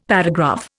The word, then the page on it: paragraph: Indian